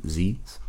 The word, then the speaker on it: zine: We had students, we will have the club make their own zines, which are just like, it's pronounced zine, just like the end of the word magazine.